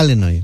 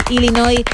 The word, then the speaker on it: Illinois: Sabes que hay, como aquí en España, hay acentos diferentes en Estados Unidos y los nativos del estado de Illinois lo pronuncian. Mira, Illinois. – Algo que a mí me enseñaron cuando me saqué un título oficial de este idioma es hablar fonéticamente para que no haya ninguna duda. Vale. Y si uno busca ese nombre fonéticamente, es Illinois. Así que Illinois y la S. Así que bravo, sin pronunciar la S final, Illinois, una vez más, tu apunte de geografía que nos viene muy bien, ¿eh?